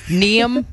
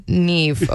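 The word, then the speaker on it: Niamh: Like, Neam McDonald? – No! – No, it's actually Neve, okay?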